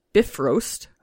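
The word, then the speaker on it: Bifröst: The Bifrost, pronounced Bifrost.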